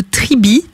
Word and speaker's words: Tribee: c'est le principe de Tribi, qui s'écrit T-R-I-B-E-E.